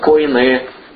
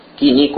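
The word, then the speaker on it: койне: Иногда, если правильно там по буквам греческим читать, коине, кини, коине, это, ну, разные произношения, но...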